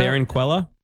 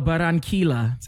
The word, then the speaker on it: Barranquilla: Barranquilla? – No, Barranquilla.